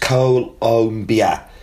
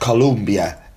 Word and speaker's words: Colombia: The country's name is pronounced Colombia, not Columbia, as I keep saying. So Colombia, not Colombia. It's an interesting fact, isn't it? Even correcting myself.